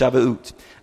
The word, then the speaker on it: Shavuot: Shavuot uh, is how I believe that it's pronounced, Shavuot.